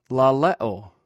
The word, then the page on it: lalew: greek